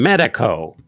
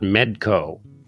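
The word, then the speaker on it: Medeco: And oh my God, you pronounce them Medico not Medco.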